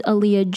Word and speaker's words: Aaliyah: My name is Aaliyah Jade and it's spelled with two A's, which is why the name is BB with double A.